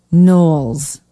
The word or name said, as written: knowles